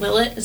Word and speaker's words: Willett: I hope I'm saying that name right. Willett, is that how you guys pronounce it? – Yeah.